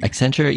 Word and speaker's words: Accenture: Accenture, yeah, they're like a consulting company.